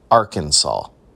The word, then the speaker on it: Arkansas: So there was legislation that was submitted and passed through the legislature there in the state that said, we are not going to be known as Arkansas. The correct pronunciation is Arkansas.